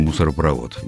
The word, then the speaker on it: мусоропровод: Ну, конечно, «мусоропровод». Все довольно просто. Ведь у слова этого «мусоропровод» есть второй корень – «провод». – Угу. – И образован этот корень от этого корня глагол «проводит», да? Проводить. Mm. Отсюда и ударение такое.